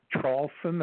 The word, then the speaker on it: Traulsen: Yes, you just put my last name, which is Traulsen. That's spelled T like Tom, R-A-U-L-S-E-N like Nancy, then the word and, and then Trump, T-R-U-M-P.